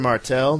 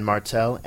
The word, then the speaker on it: Martel: You can follow me at Mr. Martel. That's Mr. spelled out. And Martel, M-A-R-T-E-L, with a little underscore in between.